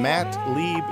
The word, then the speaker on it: Lieb: And I am on Instagram at Matt Lieb Jokes. Last name is spelled L-I-E as in eagle, B as in boy.